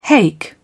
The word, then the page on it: hake: British English